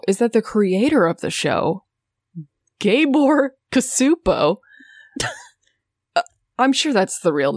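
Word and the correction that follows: Csupó: I think it's pronounced Shupo.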